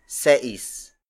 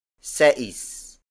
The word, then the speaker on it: seis: Cinco pesos is spelled as S-I-N-G-K-O space P-E-S-O-S. Again, the Tagalized Spanish word for 5 is cinco. There are 2 ways to pronounce and spell the Tagalized Spanish word for 6. The first one is seis. Seis. Seis. Seis is spelled as S-E-I-S. This variation appears in the University of the Philippines Filipino Dictionary, but it is rarely used. Instead, most Filipinos use sais. Sais. Sais. Sais is spelled as S-A-I-S.